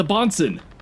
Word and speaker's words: boatswain: How do you say that word? – The bonson.